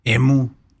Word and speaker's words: emu: ¿Ah, lleva acento en la U, emu.